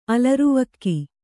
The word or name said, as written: ಅಲರ್ವಕ್ಕಿ